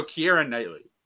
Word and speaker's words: Keira Knightley: So, Keira Knightley.